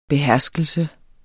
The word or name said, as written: beherskelse